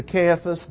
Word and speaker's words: Caiaphas: Get his name right. C-A-I-A-P-H-A-S, Caiaphas next.